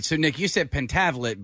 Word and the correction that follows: Pentaveret: but it's spelled Pentaveret? – Pentaveret. Well, okay. Yes, I said Pentavlet. I added an L by accident.